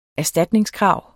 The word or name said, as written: erstatningskrav